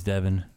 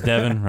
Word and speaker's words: Devin: I said my name's Devin, rhymes with heaven, which is where I'll take you, baby.